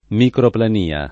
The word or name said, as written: microplania